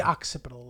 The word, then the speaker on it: occipital: This looks like you're the occipital lobe. The occipital lobe. – The occipital lobe.